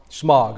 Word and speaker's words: Smaug: We need to think about a dragon like Smaug from Lord of the Rings. Am I saying that right? Smaug? Something like that. Smaug. All right, thank you.